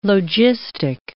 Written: logistic

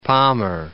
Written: Palmer Archipelago

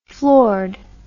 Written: floored

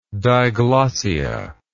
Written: diglossia

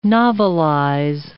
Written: novelize

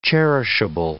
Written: cherishable

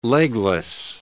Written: legless

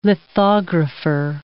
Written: lithographer

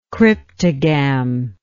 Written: cryptogamy

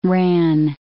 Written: ran

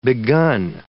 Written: begun

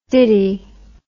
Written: diddy

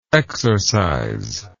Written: exorciser

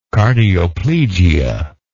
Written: cardioplegia

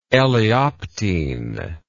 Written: elaeoptene

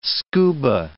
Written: scuba

